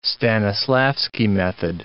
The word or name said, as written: Stanislavski method